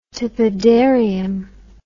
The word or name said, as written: tepidarium